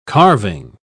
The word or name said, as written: carving